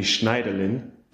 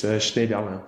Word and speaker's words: Schneiderlin: and in German it would be Schneiderlin, but in French it's Schneiderlin.